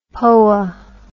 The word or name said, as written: poa